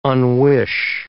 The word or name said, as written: unwish